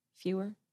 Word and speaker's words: Feuer: F-E-U-E-R. Feuer?